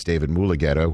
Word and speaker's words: Mulugeta: And by the way, Steve Wyche, you're showing off your chops as a broadcaster right there by gently correcting me on me mispronouncing the name of the agent. It's David Mulugeta, which I know, so I appreciate you doing that.